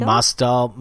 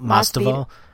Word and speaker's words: Masvidal: Masdal, Masvidal.